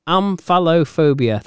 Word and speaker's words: omphalophobia: Do you know anyone that's got omphalophobia? How's that pronounced? – Omphalophobia.